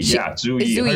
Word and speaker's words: Zhu Yi: Do I say it right? Zhu Yi. – Zhu Yi.